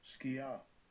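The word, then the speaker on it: skia (σκιά): Strong's G, 4639, skia.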